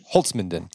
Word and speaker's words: Holzminden: Is that how you pronounce it? – Holzminden.